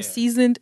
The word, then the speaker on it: Szned: And the Instagram is Seasoned, but they spell it S-Z-N-E-D series. Mm-hmm. So I'm saying it right, I hope, right? Yeah. Seasoned series.